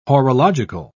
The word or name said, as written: horological